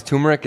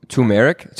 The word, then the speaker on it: turmeric: It's funny because turmeric is uh, a it's an interesting word, kind of like February, where it, it has like a silent R, and so turmeric is like uh, it's the what is it?